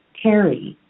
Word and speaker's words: Terry: Tear, like you're going to tear a piece of paper. And it ends with the E sound, just like the letter E. Terry.